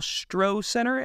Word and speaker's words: Stroh Center: Y'all are moving out of Anderson into the basketball arena that they built, you know, for the basketball Stroh Center, I believe is how you pronounce it. I don't know yes. – if that's right. – Yeah, the Stroh yes. Center.